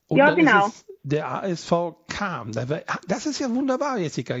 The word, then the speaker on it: Cham: Jetzt wissen wir endlich mal, wie dieser Ort ausgesprochen wird, der sich ja mit C-H-A-M schreibt. Und wir Flachland-Tiroler wahrscheinlich immer Scham gesagt haben oder sowas, oder Cham oder was. Also er spricht sich Kam, haben wir schon wieder was gelernt.